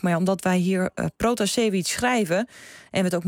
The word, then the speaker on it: Pratasevich: En ons hier in Hilversum, Protasevich. – Ja, het scheelt één letter, maar het is toch anders? – Het is anders, inderdaad. Dat klopt, zit verschil in. En ja, die Russische uitspraak officieel is Pratasevich.